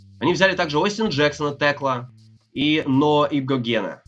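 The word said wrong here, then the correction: Igbinoghene: Да. Игбиногена. Правильно, так, по-моему, фамилия произносится.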